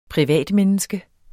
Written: privatmenneske

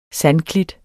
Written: sandklit

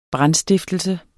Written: brandstiftelse

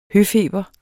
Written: høfeber

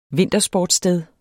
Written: vintersportssted

